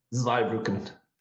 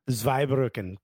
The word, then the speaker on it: Zweibrücken: And speaking of Germany, you spent a little time in, I'm going to mispronounce it, Zweibrücken. – Zweibrücken. – Zweibrücken, great.